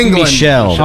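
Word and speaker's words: Michel: The Coast City Emeralds selects Sonny Michael, running back. – It's pronounced Michelle.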